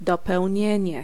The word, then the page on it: objet: [ɔb.ʒɛ]; /ɔb.ʒe/